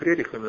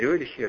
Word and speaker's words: Рерихи: среди толкователей снов, среди спиритизма, там искали для себя учителей, а эти побежали там к Рерихам, к этим, понимаете, Рерихи, оказывается, произносим у всех Рерихами, но они Рерихи, оказывается, на самом деле, я так не обращал внимания, вот, что ее там у них оказывается.